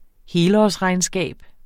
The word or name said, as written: helårsregnskab